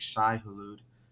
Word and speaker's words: Shai-Hulud: i think I'm gonna butcher the pronunciation, but it's like Shai-Hulud.